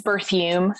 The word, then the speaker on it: Berthume: It's Berthume, so it rhymes with perfume.